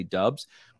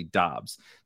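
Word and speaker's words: Doubs: So if you listen to a lot of like content providers, they'll probably say Dubs because his like college announcers and stuff called him Dubs. And he was like too nice to tell them that it's actually Dobbs.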